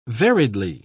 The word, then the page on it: variedly: British English